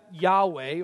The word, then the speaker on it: Yahweh: We would pronounce that Yahweh, or God's personal name.